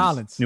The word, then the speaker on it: New Orleans: How do you pronounce it? New Orleans or New Orleans? – Narlins.